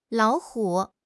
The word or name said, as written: lao3 hu3